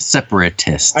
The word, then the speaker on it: separatist: You say it's separist. – Yeah, you said that a lot too. Separist. – Compared to what? – Separatist.